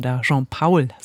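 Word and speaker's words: Jean Paul: Und Jean-Paul, so spricht man ihn aus, meint, finde ich auch sehr schön.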